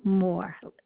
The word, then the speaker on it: Mohr: Philomena Moore. It's spelled M-O-H-R.